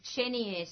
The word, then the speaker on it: Chenier: La Ossienne is at 18 Chenier Street. That's spelled C-H-E-N-I-E-R. Chenier Street in Rye.